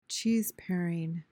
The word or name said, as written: cheeseparing